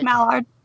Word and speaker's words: Maillard: Is that, did I say it right? – Maillard. – Maillard. – Yeah.